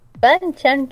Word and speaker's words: banchan: Eh, pero se sirve directamente como banchan. Banchan, que para que, sepan, eh, es lo que yo, es lo que yo explicaba. Banchan, eh, pronunciado también como banchan,